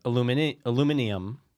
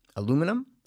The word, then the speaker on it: aluminium: It's like how the British say aluminium. – Aluminum? – Aluminium. – Aluminium. – That's how they say it.